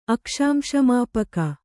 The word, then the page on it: ಅಕ್ಷಾಂಶಮಾಪಕ: Kannada